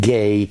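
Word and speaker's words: gay (γῆ): The word earthly is G-E. It's pronounced gay.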